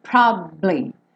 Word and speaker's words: probably: Então, ele faz como se fosse um B, ele fica um pouquinho mais de tempo com o lábio fechado no B. Probably. Probably. Tá? Então não é probably. Se você falar probably, vai soar como se só tivesse duas sílabas, mas você descansa um tempinho ali.